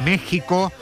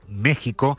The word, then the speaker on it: México: Vamos, porque lo vemos venir, está en México, no en México. Se escribe con X, pero se pronuncia con J, ese país que no existió hasta que llegaron los españoles, según Ayuso.